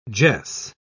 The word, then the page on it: jess: British English